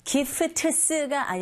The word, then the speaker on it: gifts: Gifts가 아니라 gifts. Gifts. P-t-s. 무성음 자음의 연속이죠. 세 개의 자음이 쭈르륵 연결될 땐 중간에 t 사운드 거의 안 들리게 발음하시면 됩니다.